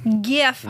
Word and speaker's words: GIF: Is it GIF or GIF? – GIF. – GIF, GIF? – GIF is peanut butter. GIF, hard G.